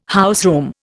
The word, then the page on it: houseroom: Indian